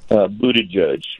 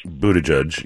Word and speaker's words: Buttigieg: Buttigieg. – Buttigieg, yes, Pete Buttigieg.